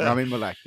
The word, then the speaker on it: Rami Malek: Rami Malek, yes.